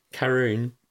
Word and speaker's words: Karun: Karim Chan... – Karun. – Karim. – Karun. K-A-R-U-N.